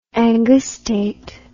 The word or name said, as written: angustate